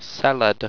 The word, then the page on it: seled: owstwecian